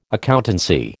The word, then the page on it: accountancy: American English